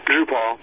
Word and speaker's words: Drupal: Drupal. – Because it sounds like RuPaul, so you got to say Drupal, right?